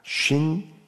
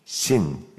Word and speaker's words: 쉰: 쉰이라고도 하고 신이라고도 합니다. 쉰, 신. 이제 두 가지 발음으로 다 나오는데 여기서 나오는 단어들이 이제 161절 처음에 나오는 고관이라는 단어입니다.